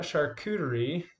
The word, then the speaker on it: charcuterie: So when we talk about charcuterie, that's how you pronounce it. It's a French term,